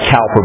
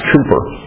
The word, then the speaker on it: Cowper: I know it looks like Cowper, but it's pronounced Cooper.